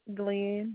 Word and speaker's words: Glenn: You say A-R-O-N? – Shanarioen. – R-I-O-N. Okay, and your last name? – Glenn, G-L-E-N-N.